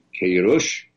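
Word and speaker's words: Queiroz: و تا قبل از آقای همین آقای پرتغالی کیروش تلفظ میشه اسمش و اینها، ما این تیم شلخته بود از نظر مدیریت و اینا، اما روبراه کرده.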